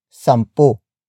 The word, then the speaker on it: sampu: For example, higit kumulang sampu. Higit kumulang sampu. Higit kumulang sampu. It means more or less 10, or 10, more or less. Word for word, it's higit kumulang sampu. Higit kumulang sampu.